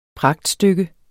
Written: pragtstykke

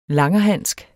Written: langerhansk

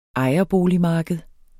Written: ejerboligmarked

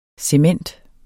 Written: cement

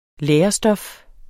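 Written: LÆRESTOF

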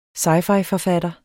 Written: sci-fi-forfatter

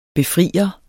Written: befrier

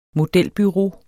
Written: modelbureau

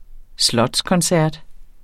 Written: slotskoncert

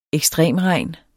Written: ekstremregn

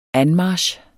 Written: anmarch

